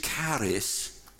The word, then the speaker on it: charis: And that begins with a word which we would pronounce in English charis, C-H-A-R-S, which is simply the word for grace.